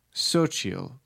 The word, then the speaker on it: Xochitl: This is my hipster list, and it is Sochil tip chips. Sochil chips. Maybe Ben Robbins and his Taco Bell bashing can figure out how to say this name. It's Xochitl.